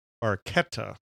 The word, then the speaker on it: Barchetta: Let's go to track two, Red Barchetta, or if you're Italian, Red Barchetta is how they pronounce it.